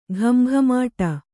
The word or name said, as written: ಘಮಘಮಾಟ